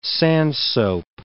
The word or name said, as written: sandsoap